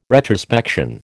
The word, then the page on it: retrospection: American English